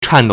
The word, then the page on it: 颤抖: 顫抖; chàndǒu